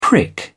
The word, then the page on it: prick: British English